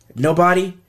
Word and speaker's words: NBDY: There's a person called, it's called, his name is spelled NBDY. Nobody. NBDY.